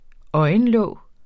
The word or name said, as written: øjenlåg